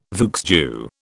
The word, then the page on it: vieux jeu: British English